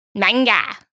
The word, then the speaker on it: manga: Manga? – Well, that's closer to how it's pronounced. – Ah, manga. It's fucking manga, mate.